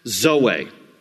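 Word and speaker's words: Zoe (ζωή): Z-O-E. Usually, for a girl's name, it's pronounced Zoe, but in the Greek, the accent is on the last part, Zoe, and it means life, and it means a fulfilled life.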